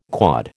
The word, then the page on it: quod: American English